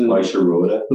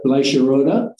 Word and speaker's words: Bleicherode: Bleicherode. – Bleicherode.